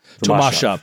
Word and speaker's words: Tomaszów: About Polish is that almost every single word, the emphasis is put on the second to last syllable. – Yes. – Like they're very routine that Tomaszów.